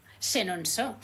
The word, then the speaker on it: Chenonceau: Chenonceau, muy bien, muy buena la pronunciación, ¿eh? – Bueno, genial.